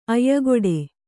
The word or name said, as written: ಆಯಕಟ್ಟು